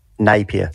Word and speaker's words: Napier: Napier. Did I pronounce that right, Napier? To watch the Warriors beat the Broncos. How do you pronounce that, mate? – Napier. – Napier, my apologies to everyone at Napier.